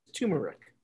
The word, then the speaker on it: turmeric: You pronounced it phonetically correct, but I believe that it is acceptable to call it turmeric.